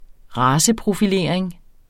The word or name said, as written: raceprofilering